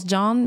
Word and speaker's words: Jeanne: Ça se prononce John, mais ça s'écrit comme le nom Jeanne.